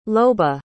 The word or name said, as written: loba